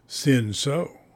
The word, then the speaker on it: Cinsault: Show off and maybe be suggestive by pronouncing it correctly. Cinsault.